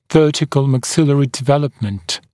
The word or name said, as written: vertical maxillary development